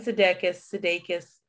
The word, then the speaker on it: Sudeikis: So basically Jason Sudeikis, I don't know if I'm saying it right.